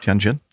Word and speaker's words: Tianjin: Airbus sent an A330-800 test aircraft from Toulouse to Tianjin. Is that how you pronounce that? – Tianjin.